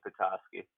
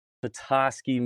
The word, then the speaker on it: Petoskey: Is a beautiful hunk of a man from Petoskey, is that, am I saying that right, Michigan? – Petoskey is what I hear, but it's actually Petoskey. – Petoskey